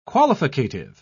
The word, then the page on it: qualificative: British English